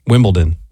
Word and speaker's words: Wimbledon: It's D-O-N. – Like Downton. – Okay, hold on. Say it correctly again. – Wimbledon.